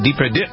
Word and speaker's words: Depardieu: Depardieu.